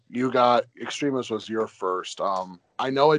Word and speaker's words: Extremis: And my comic guy gave me the Warren Ellis Extremis. Is that how you pronounce it? – Yes. Yeah.